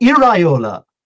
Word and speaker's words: Iraola: And just as a footnote, here's how you remember how to pronounce the Bournemouth manager's name. – Go on. – Here we go. We've got Guardiola. They've got Iraola. – Ah, Iriola, okay.